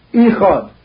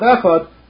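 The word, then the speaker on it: echad (אחד): It shouldn't look like you're saying ichad. Instead of echad, you're mispronouncing it to ichad.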